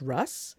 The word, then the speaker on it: Rus: I think I'm more surprised that it's not Rus, that it's Russ.